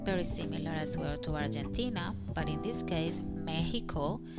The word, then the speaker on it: Argentina: So Argentina is very similar pronunciation in English, but the difference is the letter G. When I say it in English, Argentina, Argentina, is unreally pronouncing the G quite strong. Argentina, and in Spanish, is more like the G is like a J.